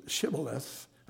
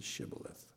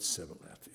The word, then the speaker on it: Shibboleth: They had a hard time pronouncing the H in Shibboleth and said Sibboleth instead, therefore giving themselves away.